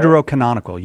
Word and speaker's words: Deuterocanonical: Was the, as you guys call them, Deuterochronicle? I think that's how you say it. – Deuterocanonical, yes.